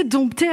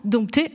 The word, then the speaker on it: dompter: Et l'Académie va changer plusieurs fois d'avis, mais finalement en 1832, le 6e dictionnaire de l'Académie valide définitivement « dompter » avec un « p ». Mais attention, elle dit qu'il faut écrire le « p », mais surtout ne pas le prononcer.